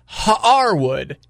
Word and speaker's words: Haarwood: This is Mr. Not Harwood, it's Mr. Haarwood. – What? Haarwood? – Yeah. It's spelled H-A-A-R Wood. But anytime Kramer mentions him by name, he has a little stutter in the name. It's Haarwood.